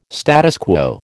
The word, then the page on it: status quo: American English